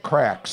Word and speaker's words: craic: But it's C-E-O-L-L, C-E-O-L, that's Kjol, ampersand. Looks like the thing on the Cheerios box. Yeah. And crack, C-R-A-I-C, right? – What's a crack?